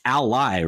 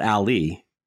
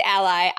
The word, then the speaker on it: Ally: Like it's Ally, right? Not Ali. – Yeah, it's Ally.